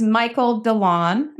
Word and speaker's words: Michael DeLon: With me today is Michael DeLon. Am I saying that right, Michael? – You are saying that exactly right. Like you're going to go outside and mow DeLon.